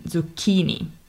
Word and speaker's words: zucchini: This time it's the middle syllable, so the key in zucchini, is spelled C plus H. So again, we get this hard K sound.